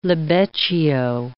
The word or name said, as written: libeccio